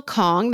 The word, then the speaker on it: Khong: Pre-order Real Americans by Rachel Kong. That's spelled K-H-O-N-G.